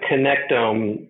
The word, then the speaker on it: connectome: I'm probably sure, but a connect connectome is that how you say it? – Yeah, that's exactly right. So we talk about the connectome, and we talk about it in a couple of ways.